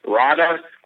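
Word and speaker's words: Raanta: And then last night they had R-A-A-T-A left to have Dana do the pronunciation. Rada.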